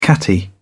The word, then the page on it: catty: British English